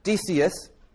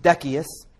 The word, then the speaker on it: Decius: Named after the emperor, Decius, sometimes pronounced Decius, D-E-C-I-U-S, he only ruled for 2 years, fortunately for the Christians.